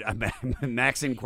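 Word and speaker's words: Maxinquaye: I love, what is it, Maxinique? I think that's how you say it. – I thought it was Maxinque. I don't know how you say it. – You know what? – Cool. – You're probably saying it right. Maxinque, that's even better.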